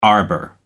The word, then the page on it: arbour: American English